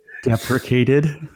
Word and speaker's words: deprecated: Well, they have finally fully, and I'm waiting to correct my pronunciation here. But they have fully depreciated. – You bugger. – Go ahead. – Deprecated.